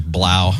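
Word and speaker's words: Blough: Purdue Boilermaker, Blau. – Blaus are going to blow. – The O-U-G-H is pronounced.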